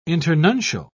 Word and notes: internuncial: British English

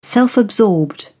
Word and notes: self-absorbed: British English

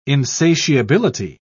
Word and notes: insatiability: British English

